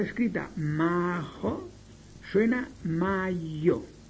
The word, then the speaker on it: majo: Ejemplo, mayo se escribe en esperanto majo, M-A-J-O, como le están oyendo. Majo se lee mayo, mayo.